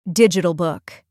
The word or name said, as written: digital book